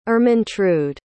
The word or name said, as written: Ermentrude